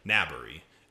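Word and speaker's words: Naberrie: Nabre, however you pronounce it. – Naberi. – I heard a lot of different pronunciations, and the most common one I heard was Naberi. So yep. I'm going to go with that.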